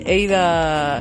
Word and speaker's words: Ada: eida Lovelace, ¿no? Creo que me dijo Spectrum que se pronunciaba así. Yo para mí es Ada.